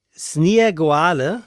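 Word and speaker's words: Snieguolė: Ihr zweiter Name, den spricht man aus, ich habe es mir irgendwo in Lautschrift aufgeschrieben und finde es jetzt natürlich, auf die, doch hier, spricht sich aus Sniegoale, mhm, und das heißt Schneewittchen auf Litauisch.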